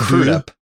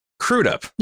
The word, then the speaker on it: Crudup: I learned how to pronounce his name correctly because of this movie. Because I watched an interview with him, like a reunion thing on a podcast. And it's apparently Crudup.